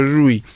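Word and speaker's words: жуй (瑞): Так называемых жуй, по-китайски это называется, значит, не путать с глаголом жевать русским, значит, так по-китайски произносится жуй.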